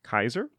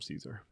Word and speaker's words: Caesar: So you'd say like Ave Romano, you'd say Awe Romano. It's more like hard consonants, which Latin is filled with, versus soft consonants. But which is why within the Legion, he's pronounced Kaiser, and without, everyone just calls him Caesar.